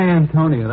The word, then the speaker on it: Antonia: My Antonia, that's all I know. – Yes. I think the name is pronounced Antonia.